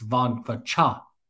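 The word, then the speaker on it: Vongphachanh: I went on Sports Nation, and I thought that I had nailed AJ Vongquachong, but according to the Sports Nation guys, it's Vongquachong. Like the NH is almost silent.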